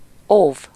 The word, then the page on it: guard: American English; [gɑː(r)d]